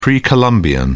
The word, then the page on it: pre-columbian: British English